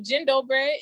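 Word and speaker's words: Dzień dobry: So Jin Dobre is how you say basically good day or hello. That's how you greet.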